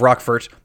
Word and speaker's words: Roquefort: Well, it's actually pronounced Roquefort. Roquefort, yes.